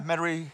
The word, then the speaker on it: Metairie: How do you think you pronounce this? Metairie, Louisiana? – Yeah, Metairie.